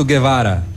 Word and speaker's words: Guevara: Ernesto Che Guevara, é, Ernesto Guevara, Ernesto Guevara, conhecido como Che Guevara. Guevara, não é Gue, é Gui. – Guevara. É Guevara a pronúncia.